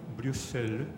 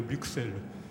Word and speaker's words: Bruxelles: Il s'agit de la capitale de la Belgique, c'est-à-dire Bruxelles, ou bien Bruxelles. J'entends très souvent dans la poste de radio la, de, France Culture, dont les présentateurs prononcent Bruxelles, tandis que quand je me rends à cette capitale, le petit peuple de cette ville prononçait Bruxelles. Donc il y a deux prononciations.